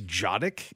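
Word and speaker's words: dziadek: And I think it's Jadek is the way that it's pronounced, but it's D-Z-I-A-D-E-K.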